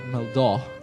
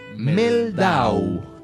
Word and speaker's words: Mehldau: Cuyo nombre es Brad Meldau. – Brad... Meldau. – Meldau, ¿se escribe? – Sí. M-E-H-L-D-A-U. Es un pianista norteamericano. – Meldau. – No, Meldau. – Meldau. – Meldau. M-E-H. Meldau.